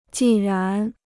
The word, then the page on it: 既然: jì rán